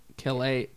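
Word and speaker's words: Caillat: Colby Kelly, because she's like French.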